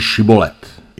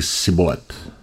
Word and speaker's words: šibolet: Tak mu řekli, řekni šibolet. Jenže Efratejští tuhle výslovnost neznali a řekli sibolet. No a když řekli sibolet, tak to byla špatná odpověď a stálo je to život.